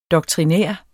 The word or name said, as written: doktrinær